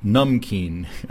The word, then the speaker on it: namkeen: Namkeen is pronounced numkeen. Okay, I've been pronouncing it wrong. Numkeen. Well, there's an A instead of a U, but we'll call it numkeen.